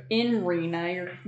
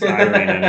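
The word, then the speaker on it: Ireena: Yeah, so in Rena, you're pronouncing it wrong. – No, I'm gonna Death of Strahd it. Yeah, it's Irina, no.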